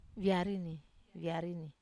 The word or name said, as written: viarini